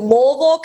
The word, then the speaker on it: Louisville: You're based in Louisville, Kentucky.